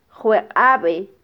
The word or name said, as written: jweqabe